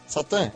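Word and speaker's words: Šatan: Tinha um jogador tchecoslováquio que o sobrenome dele pronunciado era Chatan, só que a pronúncia era Satan, S-A-T-A-N.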